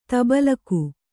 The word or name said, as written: ತಬಲಕು